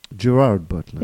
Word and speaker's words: Gerard Butler: It's with, like, Jared Butler and, like, a bunch of Irish actors. – Gerard Butler. – Yeah, I always say that wrong, yeah.